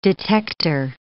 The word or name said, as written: detector